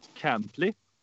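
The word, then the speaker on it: Cantlay: Köpka, Thomas, Dustin Johnson, Cantley. Är det korrekt uttalat? – Ja. Ja, det blir bra.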